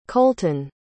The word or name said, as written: kolton